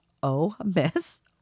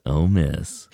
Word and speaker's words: OMIS: I will not say all of the nets are like this are super friendly, but OMIS, pronounced OMIS, or OM, International Sideband Society. – OMIS.